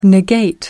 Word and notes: negate: American English

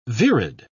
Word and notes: virid: British English

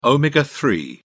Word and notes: omega-3: British English

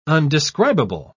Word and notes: undescribable: British English

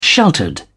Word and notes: sheltered: British English

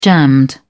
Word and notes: jammed: British English

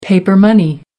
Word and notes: paper money: American English

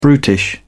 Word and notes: brutish: British English

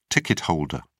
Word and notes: ticket holder: British English

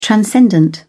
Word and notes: transcendent: British English

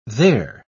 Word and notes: they're: British English

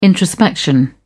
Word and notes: introspection: British English

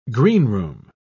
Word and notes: greenroom: British English

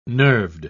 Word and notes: nerved: British English

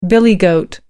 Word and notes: billy goat: American English

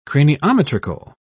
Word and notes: craniometrical: British English

